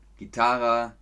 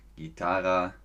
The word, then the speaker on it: Gitarre: Ihr hört also, es klingt sehr ähnlich und die Deutschen setzen da noch ein E dahinter. Gitarre, Gitarre, Gitarre, Gitarre, Gitarre, Gitarre, Gitarre. So spricht man das.